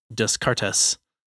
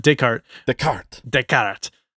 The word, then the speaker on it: Descartes: And one of the philosophers that was relevant here was Descartes, which, for those of you who don't know, looks like it's pronounced Descartes. But Descartes.